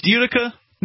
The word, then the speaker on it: Utica: Is it pronounced Utica, New York? – Yeah.